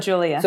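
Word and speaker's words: Giulia: So I'm like, is it still pronounced Julia? That's just the... – It's still Julia.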